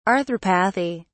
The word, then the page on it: arthropathy: British English